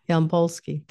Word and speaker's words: Yampolsky: I'm going to let you tell me how to say your last name so I don't slaughter it. – Yampolsky. – Thank you.